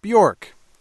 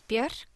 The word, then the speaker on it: Björk: She's known by a single name, which Americans tend to pronounce Björk. She pronounces it this way. – My name is Björk, and I make music.